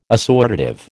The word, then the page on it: assortative: American English